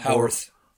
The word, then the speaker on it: Howarth: Alan Horowitz and Horowitz, right? Horowitz? – Yeah. Howarth. – Howarth and I'm sorry, I totally butchered that.